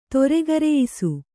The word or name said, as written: ತೊರೆಗರೆಯಿಸು